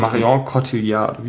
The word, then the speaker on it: Marion Cotillard: Und Marion Cotillard, wie heißt die? Genau, ja, genau, das war richtig ausgesprochen.